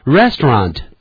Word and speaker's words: restaurant: Restaurant, 这个拼法是 R-E-S-T-A-U-R-A-N-T, 餐厅 R-E-S-T-A-U-R-A-N-T, restaurant. 也有人念成 restaurant, 但一般都是念得比较快，念成 restaurant 就好了。